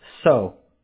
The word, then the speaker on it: Seo: It's spelled S-E-O, but it's pronounced So. I'm half Korean, half Puerto Rican. – Okay. S-E-O.